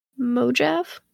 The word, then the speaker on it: Mojave: He found himself in Mojave, California. – Okay. – That's probably not how you say that one either, but whatever. No, I think that's exact pronunciation.